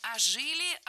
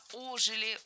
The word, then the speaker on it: ожили: Не ожили, а ожили. Ударение в этом слове ставим на приставку.